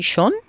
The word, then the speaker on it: schon: Seit wann warten, warten Sie schon? Seit wann warten Sie schon? Intenta pronunciar un poco esa O más a la Schon. Schon.